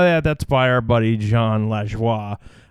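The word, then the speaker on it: Jon Lajoie: Like, I enjoy his stuff, but I didn't know that his name was actually pronounced John Lajoie. I thought it was John LaJoy.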